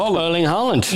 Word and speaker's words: Erling Haaland: Erling Haaland.